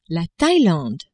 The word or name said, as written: Thailande